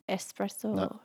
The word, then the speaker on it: espresso: Espresso. – Not espresso. Espresso. There's no X in there.